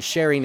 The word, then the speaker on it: Chering: This is Cher Strawberry, and that last name is spelled S-T-R-A-U-B-E-R-R-Y, Cher Strawberry. The album is called Sharing is Caring, and Sharing in this case is spelled C-H-E-R-I-N-G, like her first name.